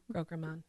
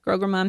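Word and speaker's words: Graograman: How are you guys saying the lion's name? Grogramon? – Grogramon. – Grogramon. – That's how I say it. – Cool. – Is that how it's said in the audio? – That sounds about right.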